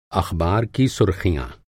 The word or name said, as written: सुर्ख़ी